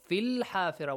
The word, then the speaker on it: في الحافرة: Why did you say في الحافرة؟ There is one ya. Why didn't you pronounce the ya? – It's not written silent. – Yeah, why is it silent? – It's written, but we'll not read.